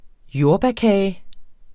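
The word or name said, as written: JORDBÆRKAGEN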